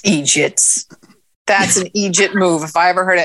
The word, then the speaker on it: eejit: It's spelled E-E-J-I-T. It's Irish and Scottish form of idiot. Egypt, long E.